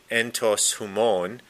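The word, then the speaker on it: entos humon (ἐντὸς ὑμῶν): And in Koine Greek and some of the papyri, we have this Greek phrase here, the entos humon is how you say it in Greek.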